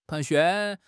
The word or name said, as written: 盘旋